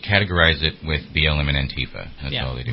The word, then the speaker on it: Antifa: And I think we should reclaim the pronunciation of Antifa. I hate that pronunciation. It should be Antifa. – Antifa. – Antifa. – The emphasis should be on the ah.